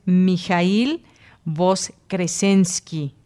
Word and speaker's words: Mijail Voskresensky: Y bueno, pues me escribió rápidamente Dulce Wet y me dice que su nombre es Mijail Voskresensky. Así que, pues bueno, ahí está la pronunciación correcta